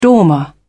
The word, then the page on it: dormer: British English